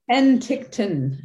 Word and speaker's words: Penticton: So you were in Penticton. Do I pronounce that right? Penticton? – Penticton.